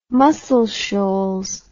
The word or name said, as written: muscle shoals